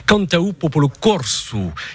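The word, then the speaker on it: Canta u Populu Corsu: C'est Mathieu qui représente le groupe Cantau Popolo Corso. J'ai essayé de le prononcer le mieux possible. Est-ce que je m'en suis sorti, Mathieu? – C'est parfait, c'est très bien.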